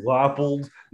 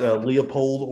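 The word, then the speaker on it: Leipold: It's Lance Leopold, not Leopold, or as you like to call him, Lance Leotard.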